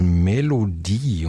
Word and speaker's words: Melodie: Das dritte Album ist dies, inzwischen mit dem schönen Titel Melodie, und das darf ich deutsch aussprechen, denn das Wort endet mit IE in diesem Falle.